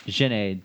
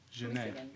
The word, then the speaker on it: Jhené: Wait, like, Janelle, what? – Jhené. Jhené Aiko. – J-H? You can't put those. – There's an accent on the E. – Next to each other. – Jhené.